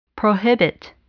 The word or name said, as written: prohibit